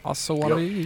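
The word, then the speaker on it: Osawatomie: August 7th, he burns a Georgia settlement of Osawatomi. How do you pronounce that? – Yeah, I think you nailed it. – Yep. – Osawatomi. – Osawatomi? – Osawatomi.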